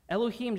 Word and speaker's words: Elohim: The word there is Elohim.